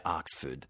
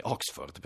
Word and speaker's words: Oxford: si sbecchettano perché lei cerca di pronunciare la parola, il nome della località inglese Oxford, nel modo più inglese possibile, più britannico possibile, quindi dice Oxford. E lui la prende in giro dicendo, ma no, non bisogna dire Oxford, bisogna dire Oxford, perché siamo in Italia e si può benissimo adattarlo un pochino questo inglese.